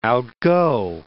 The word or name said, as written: outgo